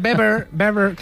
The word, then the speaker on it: Weber: Está Manfred Weber, que se dice Weber, sí. No se dice Weber, ¿vale? Sí, sí. Se dice Manfred Weber.